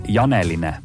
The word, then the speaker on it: Janeline: Nii et minge vaadake see ka siis Facebooki lehele Janeline. Niimoodi kirjutategi, nagu ma hääldasin.